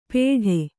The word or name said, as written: ಪೇಠೆ